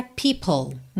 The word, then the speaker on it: people: A gente tem POPLE e a pronúncia people. Então, especialmente para quem está começando, para quem está vendo essa palavra aí pela primeira vez, atenção, tá? People. Então, you know how people are. Você já junta o L de people em are, porque o E final de people não é pronunciado, certo? O som termina no L, people.